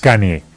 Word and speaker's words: Kanye: ¿Kanye West o Kanye West? – Kanye.